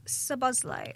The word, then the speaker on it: Szoboszlai: Well, Dominic Szoboszlai. – Oh, very well pronounced.